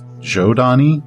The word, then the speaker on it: Zhodani: Well, it's spelled Zhodani. I've heard it's pronounced Jodani, like with a J, uh, because long, long, long ago, the ancients actually seeded many worlds with humans, and they all evolved very differently.